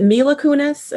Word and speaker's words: Mila Kunis: So apparently, is it Mila Kunis? Am I saying her name right? Mila? – Yeah.